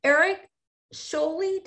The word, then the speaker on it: Erik Sjolie: So Erik Sjolie, did I say it right? – Sjolie, yeah, that is correct.